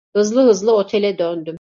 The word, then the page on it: otele: Turkish